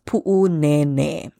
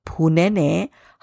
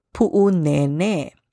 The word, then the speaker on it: Pu'unene: Now, this place often gets mispronounced as Punene. However, there is an okina there, a glottal stop in Pu'u. And there are two kahako, two makrons over the letter E in Nene. So we want to elongate those vowels. So the correct pronunciation is Pu'unene.